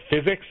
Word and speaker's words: Fizzics: One of my favorite things coming out just in our stores about 2 weeks ago, it's called Physics. It's spelled F-I-Z-Z-I-C-S.